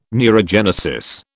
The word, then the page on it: neurogenesis: British English